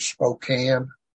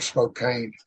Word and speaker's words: Spokane: Lake had the healing rooms up in, I think they pronounce it Spokane, and not Spokane, like we do down in Arkansas.